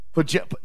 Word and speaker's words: Podziemski: Podziemski.